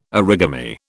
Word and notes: origami: British English

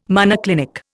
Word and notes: monoclinic: Indian

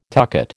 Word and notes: tucket: American English

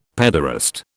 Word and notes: pederast: British English

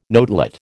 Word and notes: notelet: American English

